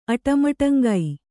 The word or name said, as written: ಅಟಮಟಂಗೈ